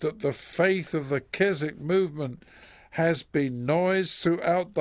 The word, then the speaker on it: Keswick: You pronounce it sometimes Keswick, but it's actually Keswick.